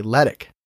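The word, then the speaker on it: letiche: It's L-E-T-I-C-H-E. I would have said like Letich or something, but it's okay. Letic.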